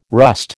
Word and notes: rust: American English

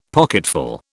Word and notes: pocketful: British English

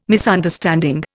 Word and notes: Misunderstanding: Indian

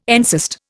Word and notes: encyst: Indian

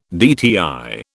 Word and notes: DTI: British English

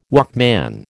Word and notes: Walkman: American English

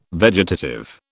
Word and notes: vegetative: British English

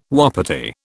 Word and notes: wapiti: British English

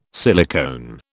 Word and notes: silicone: British English

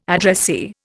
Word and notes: addressee: Indian

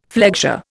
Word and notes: flexure: Indian

